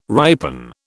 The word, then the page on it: ripen: British English